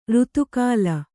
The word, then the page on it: ಋತುಕಾಲ: Kannada